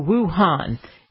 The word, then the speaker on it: Wuhan: I found out the correct name because I've heard 10 different pronunciations. That's a slight exaggeration. A few. So Wuhan. In Wuhan, they had to build a massive hospital in 10 days because of the coronavirus.